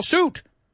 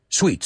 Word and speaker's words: suit: Lo que me desconcierta es cómo lo pronunciáis. Sweet, sweet, sweet, y sweet. Vamos, que sweet, que venga, que sweet. Olvídate la I. Que desaparezca. Get rid of that I.